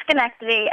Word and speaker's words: Schenectady: How do you say that correctly? – It's Schenectady, and I'm actually in Oneonta, New York at Hartwick College right now.